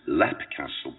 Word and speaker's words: Leap Castle: But it was an investigation in Ireland, in Southern Ireland, at a castle called, pronounced Lep Castle, but spelled Leap Castle.